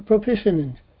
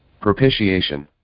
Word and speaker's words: propitiation: Not that we loved God, but that he loved us and sent his Son to be a propitiation. Let me see how to pronounce that. I always... – Propitiation.